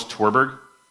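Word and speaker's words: Tverberg: In this book, the author is Lois, I think it's Torberg. It's got a V in it, but I think it's, when I've heard it pronounced, it sounds more like a U. So it's Lois Torberg.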